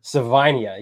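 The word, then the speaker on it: Savaiinaea: Jonas Savinia from Arizona, who I think is better than the Arizona. – I think you said it was Savinia. – Savinia, you're right. Savinia, yeah, you're right.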